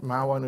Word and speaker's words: Mahwah: Is that how you pronounce it? Mahwah, New Jersey? Yeah.